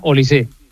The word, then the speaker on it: Olise: No, es francés, es no francés. Lleva acento, pero se pronuncia Olise.